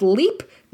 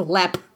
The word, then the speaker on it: Leap: Please note, it is spelled leap, This is a trap that the Irish have made to trick you into saying Leap Castle. It is not pronounced Leap. It is pronounced Lep.